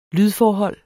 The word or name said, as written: lydforhold